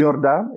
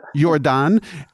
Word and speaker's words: Jordaan: Let's talk a little bit about your food tours, because you do one in a neighborhood called Jordaan. I think that's how you say it. – Yeah. – And Jordaan. Yeah. – Jordaan.